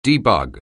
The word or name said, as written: debug